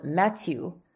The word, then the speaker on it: Mathieu: and where his name is spelled Mathieu, and is pronounced like you are sneezing halfway through it.